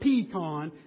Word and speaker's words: pecan: So if you go like Mississippi, Arkansas, Texas, it's the correct pronunciation, pecan. Pecan, not pecan. It's not pecan either. It's pecan.